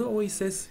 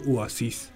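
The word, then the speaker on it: Oasis: اویسس بعضی هم اینجوری تلفظ میکنن که اواسیس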